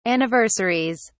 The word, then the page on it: anniversaries: British English